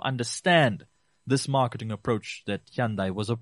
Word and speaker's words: Hyundai: Hyundai, which we sometimes mispronounce as Hyundai, Hyundai came through with one of the best value proposition, and this came through in their advertising and marketing.